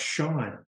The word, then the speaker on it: Sean: It's spelled S-E-A-N, but it's pronounced Sean.